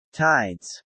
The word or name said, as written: tides